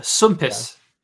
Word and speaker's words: swmpus: Pronounce this Welsh word for me. It's SWMPUS. – SWMPUS. – SWMPUS. – Yeah. Swampish? – Not too bad. It's a sumpus.